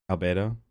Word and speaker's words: Albedo: Albedo? Is that how you pronounce it? – Albedo, yeah.